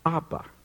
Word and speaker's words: Abba: He chose a little Aramaic word pronounced Abba. And long after the Aramaic language wasn't being used anymore, the Christians held on to that one little Aramaic word referring to God, Abba.